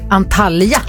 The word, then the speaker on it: Antalya: Antalya.